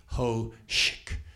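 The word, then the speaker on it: hózhǫ́: It's phonetically ho, H-O-E-S-H-K, hoshk. How it's really spelled in English is H-O-Z-H apostrophe Q.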